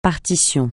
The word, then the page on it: partition: francais